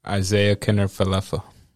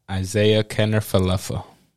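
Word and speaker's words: Isiah Kiner-Falefa: Isaiah Kinsler Fenelia. – Isaiah Kenner Falefa. – One more time for the people. – Isaiah Kenner Falefa.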